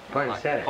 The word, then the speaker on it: poinsettias: Like Pacenif? – Yeah. – I think I pronounced it as Pacenif. It was Christmas. – Poinsettias. – Poinsettias, okay.